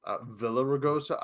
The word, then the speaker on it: Villaraigosa: It's a Villaraigosa. I don't know. – Yeah, Villaraigosa, yeah.